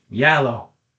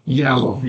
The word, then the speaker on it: giallo: It's going to be Yalo. I looked up how to pronounce Yalo. – Yalo. – Yalo. It just means yellow.